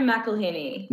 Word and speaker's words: McElhinney: Yeah, Hannah McElhaney.